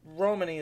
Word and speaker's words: Romani: I think it's, I've heard it pronounced both ways, but Romani is the way I hear it most commonly.